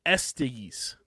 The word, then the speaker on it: Estes: Joey Estes. – Nope. – It's testes without the T.